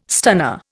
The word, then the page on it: stunner: Indian